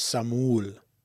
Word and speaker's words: Samuel: But I pronounce it the Samoan way, which is Samul.